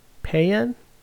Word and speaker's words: paean: Is that how you spell peon? Payan? It's spelled P-A-E-A-N. That's not peon. Peon is P-E-O-N.